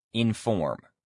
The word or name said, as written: inform